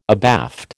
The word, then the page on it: abaft: American English